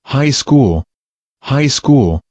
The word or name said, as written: high school